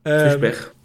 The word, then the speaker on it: Fischbach: Ich kann den Deutsch aussprechen, weil er heißt Fischbach. Ich weiß gar nicht, wie ihn Fischbach. – Fischbach.